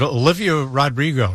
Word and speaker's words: Olivia Rodrigo: His record label lawyer's trying to sue, is it Olivia Rodrigo, right? – Yeah.